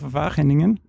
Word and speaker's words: Wageningen: You obtained your PhD in genetics at the University of Wageningen.